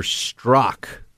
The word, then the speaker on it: Strzok: And I think it's going to get a lot worse for them on this issue of Peter Strzok, as I found out that's how we say his name.